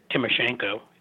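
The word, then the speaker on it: Tymoshenko: Yanukovych, and to lose sympathy for Timoshenko.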